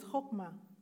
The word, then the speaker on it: chokmah: Wisdom that is mentioned in Isaiah 11:2 says, that wisdom, that word wisdom, I know it by heart now in the Hebrew, is chokmah. It's spelled C-H-O-K-M-A-H, but it's like chokmah, like H, like you pronounce it that way.